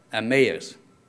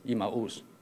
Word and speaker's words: Emmaus: You know, so what they pronounce is Emmaus, okay? But if I go to the Chinese translation, usually that tells me how to read it, because the Chinese, they just translate phonetically. And it's almost like the local language, the original language you might pronounce is Emmaus.